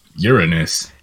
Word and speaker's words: Uranus: Uranus. – See, it's not your anus. It's there's like urine in the name when you're pronouncing Uranus.